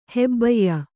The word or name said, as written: ಹೆಬ್ಬಯ್ಯ